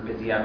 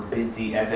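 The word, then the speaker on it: bidyeved (בדיעבד): Okay, it depends what's considered bidyevad or mechatchila. Okay, bidyevad. I guess I should define my terms for this. We're not familiar. Bidyevad or bidyeved, as it's usually pronounced, means after the fact.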